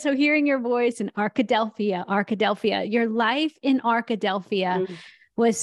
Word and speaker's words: Arkadelphia: I've never been to this town, but Arkadelphia. – Yes. – Arkadelphia.